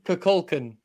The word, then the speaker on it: Kukulkan: Kukulkan is the actual pronunciation of the deity of a dead religion.